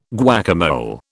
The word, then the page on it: guacamole: British English